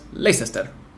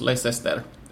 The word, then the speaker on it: Leicester: And they responded today by saying in Spanish it is pronounced Leicester. So three syllables, Leicester. The incorrect pronunciation is in Spanish the correct pronunciation. – If you are talking in Spanish, you say Leicester.